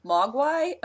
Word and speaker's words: Mogwai: Mogwai? Am I saying it right again? – Yes.